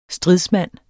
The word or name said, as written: STRIDSMANDS